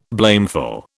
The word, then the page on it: blameful: British English